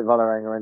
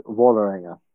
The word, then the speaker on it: Vålerenga: I mean, I called it Vålerenga until I was here for probably 6 months. Is the ah with the circle on the top is an oh, so it's Vålerenga.